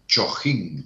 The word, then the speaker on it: Chojín: Chojín. C-H-O-J-I-N. Como Chopin, como el compositor, ¿no? Tan famoso Chopin que se escribe Chopin, pero con J, Chojín.